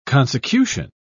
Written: consecution

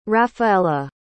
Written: raphaella

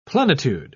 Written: plenitude

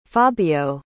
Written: fabio

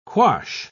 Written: quash

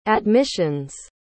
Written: admission's

